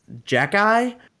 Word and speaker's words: Xhekaj: His name is pronounced Jack Eye, but it's let me spell this out for you. X H E K A J.